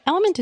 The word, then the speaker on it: LMNT: Enter Element, that's spelled L M N T, a tasty electrolyte mix with everything you need and nothing you don't. That means lots of salt with no sugar. Element is so sure you'll love their product,